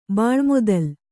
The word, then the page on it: ಬಾಳ್ಮೊದಲ್: bāḷmodal